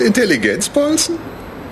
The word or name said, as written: Intelligenzbolzen